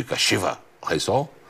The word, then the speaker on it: Kashiwa Reysol: Ficou boa essa pronúncia de Kashiwa Reysol? É assim, né, Lamir? – Ficou.